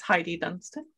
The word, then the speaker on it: Heidi Dunstan: You said it the Spanish way, Heidi Dunstan. In the German way, it's Heidi Dunstan.